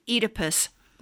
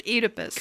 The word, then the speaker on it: Oedipus: You always insisted that we say Oedipus, not Oedipus. – Yeah. – I noticed that. – O-E is pronounced E. Phoenix and Phoebe and the god of wine, Enos, they're all O-E.